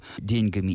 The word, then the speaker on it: деньги: что в слове «деньги» во множественном числе во всех падежах ударение должно падать все-таки на первый слог, в отличие от единственного числа.